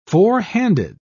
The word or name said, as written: forehanded